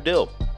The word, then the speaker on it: Dill: But for that, my name is Andrew Dill. Follow me, well, friend me on Facebook, D-I-L-L, like the pickle.